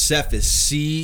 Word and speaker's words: Cephas: Yeah, it is Cephas.